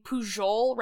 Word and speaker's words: Pujol: I'm using the Catalan pronunciation of his last name rather than the standard international Spanish pronunciation of his last name, because he is, of course, a proud member of the Catalan tribe, but also because his name pronounced in a normal Spanish accent would be Pujol.